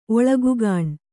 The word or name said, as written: ಒಳಗುಗಾಣ್